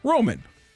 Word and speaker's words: Roman: Roman Berkey, MANROH for the pronunciation, guys. ROH-MAN, not MAWN, whatever weird one. Roman.